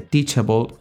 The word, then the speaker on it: Teachable: Esta otra herramienta como eh, Teachable, que es una herramienta eh, norteamericana, eh, se escribe teach, de enseñar, T-E-A-C-H, able, que es A-B-L-E. Juntos se, se lee teachable.